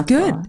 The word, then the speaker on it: Aotearoa: That's Aotearoa. Good. Aotearoa. Yes, good.